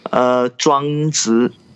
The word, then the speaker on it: Zhuangzi: Zhuangzi.